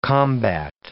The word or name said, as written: combat